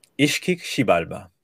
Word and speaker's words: Ixkik Xibalba: Ixkixibalba. The X is pronounced like a sh. – Ixkik Xibalba.